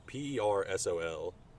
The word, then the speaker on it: Persol: So I look up Google, and Google's like, Persol. It's Persol. And so I was saying Persol or Sol, but it's like Persol.